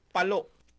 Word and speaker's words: Palo: Ano ba ang talagang tamang pagbigkas? Palo o palo? – Palo, Leyte. Palo. Opo.